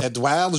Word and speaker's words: Edouard: E-D-O-U-A-R-D.